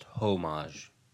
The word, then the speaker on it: homage: It's pronounced homage. Homage.